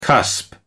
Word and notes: cusp: American English

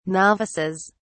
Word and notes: novices: British English

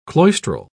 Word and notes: cloistral: British English

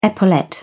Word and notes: epaulette: British English

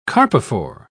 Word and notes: carpophore: British English

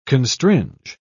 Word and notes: constringe: British English

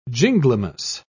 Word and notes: ginglymus: British English

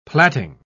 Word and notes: platting: British English

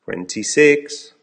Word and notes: twenty six: American English

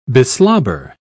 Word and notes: beslobber: British English

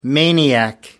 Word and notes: maniac: American English

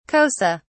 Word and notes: xhosa: British English

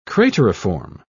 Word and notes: crateriform: British English

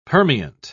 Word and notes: permeant: British English

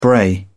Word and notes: bray: British English

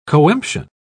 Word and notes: coemption: British English

